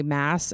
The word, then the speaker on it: Maas: And it is the wildly, wildly popular fantasy series by Sarah J. Maas, I think is how you say it. I've heard it both ways. But in the audiobook version, they say Mass, and I assume that she greenlit that.